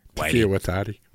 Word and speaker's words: Taika Waititi: It's adapted from the cult movie by Taika Waititi. Did I say it right? – Close enough. Taika Waititi.